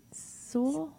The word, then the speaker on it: Seoul: She was born in Seoul.